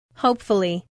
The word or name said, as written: hopefully